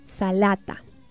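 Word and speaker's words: salata (سلطة): También tenemos la palabra ensalada que se pronuncia salata.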